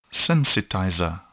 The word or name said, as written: sensitizer